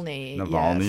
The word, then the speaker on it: Navalny: Yeah, but that went to Navalny. Hopefully I'm saying that right. And you said it was Navalny, yes.